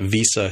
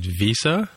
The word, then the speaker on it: Visa: It's an S, not a Z. – Visa. – You just said Visa. – Or Z. – Huh? Visa. – Yeah. – Oh, now I don't actually know how to pronounce it, though. – I do say, like Tim, I'm with Tim this time. I do say Visa with a Z sound.